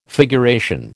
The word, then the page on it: figuration: American English